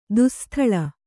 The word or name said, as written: ದುಸ್ಸ್ಥಲ